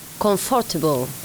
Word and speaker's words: comfortable: Ahí confortable tiene una pequeña variante en pronunciación y es que la O de for, confortable, no se va a pronunciar, apenas se oye, ¿vale? Entonces va a ser más un comfortable. Comfortable. Ok, entonces confortable, vamos a marcar la M y vamos a eliminar la segunda O de confortable, con lo cual se diría comfortable.